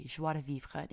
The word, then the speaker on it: joie de vivre: You guys have heard me talk about it before, but it's joie de vivre. It's spelled J-O-I-E, new word, D-E, And then the final word is V-I-V-R-E, joie de vivre.